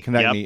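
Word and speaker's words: Konecny: I always say his name wrong. – Konechny. Yep.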